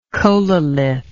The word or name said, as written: cholelith